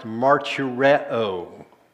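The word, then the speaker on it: martureo (μαρτυρέω): The original language, it's pronounced martureo. Martureo.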